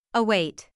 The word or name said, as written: await